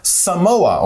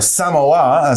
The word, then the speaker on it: Samoa: All right, and so for the last strange law, we go to the very tiny little island country of Samoa, or Samoa, as some people would pronounce it.